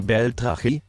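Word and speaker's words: Beltracchi: C'est Wolfgang Beltraki. Alors, je le prononce mal parce qu'en réalité, ça se dit comme ça. – Wolfgang Beltraki.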